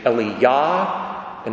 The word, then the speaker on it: Elijah: In Hebrew, you say Eliyah and Elisha, with the emphasis on the last syllable. It's the only way they sound different, but they're two different people, I assure you.